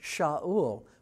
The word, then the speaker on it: Shaul: So you can hear in both of those words, asked and questioned, you can hear some of the root for the word Shaul, which we pronounce in English as Saul.